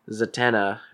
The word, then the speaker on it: Zatanna: And then Batman goes to Zatanna. Did I say her name right? Okay.